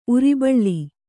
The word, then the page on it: ಉರ್ಬಳಿ: urbaḷi